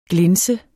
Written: GLINS

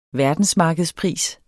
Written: verdensmarkedspris

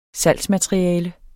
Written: salgsmateriale

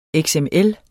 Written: XML

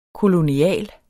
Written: kolonial

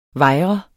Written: vejr

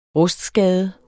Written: rustskade

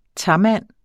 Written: tamand

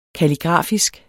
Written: kalligrafisk

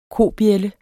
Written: kobjælde

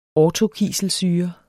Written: orthokiselsyre

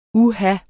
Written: uha

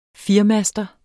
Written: firemaster

